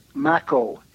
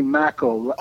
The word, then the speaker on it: Macko: Right, and then my name is Macko, Henry Macko. Oh. Rhymes with tobacco.